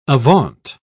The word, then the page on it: avaunt: British English